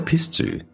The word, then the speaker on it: pistou: Spelled P-I-S-T-O-U and pronounced pistou, this is a similar sauce in ingredients and the way it's made, but there are two main differences.